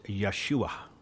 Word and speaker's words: Yeshua: And one of the ways the name of Jesus is pronounced is Yeshua.